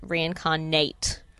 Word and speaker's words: Reincarnate: You have to pronounce it Reincarnate. – Oh, right. – Because the character's name is Nate, which is all in capitals, and it's he's sitting on a car.